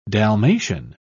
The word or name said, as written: dalmatian